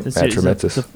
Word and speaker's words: Atramentus: Yep, Atramentus.